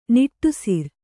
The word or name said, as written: ನಿಟ್ಟುಸಿರು